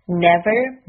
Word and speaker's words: never: 当有人对你说 sorry 的时候，可以回答 Never mind。 Never mind。好，我们来看一下发音的部分。第一个单词 never，注意英音和美音的区别在于卷舌音是否读出来了。